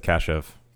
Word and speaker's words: Kashuv: Kyle Kashuv. Is that how you say his name? – It's Kashuv.